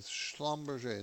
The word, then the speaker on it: Schlumberger: Schlumberger. And I'm not a very good enunciator of some of these names, so forgive me if I mess it up. 888-99-CHART. So let's talk about U.S. oil. Since Schlumberger was on there, U.S.